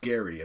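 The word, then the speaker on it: Gerry: Spelled Jerry, but pronounced Gary, apparently.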